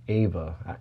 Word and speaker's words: Ava: or Ava is more accurate to the pronunciation of her name, meaning we have the masculine and the feminine within each human being.